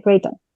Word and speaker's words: Creighton: Creighton se escribe C-R-E-I-G-H-T. – T-O-N, Creighton.